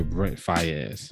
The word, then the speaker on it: Faiyaz: Let's talk about this boy Brent Faiyaz. That's how you say his name, right? – Yeah.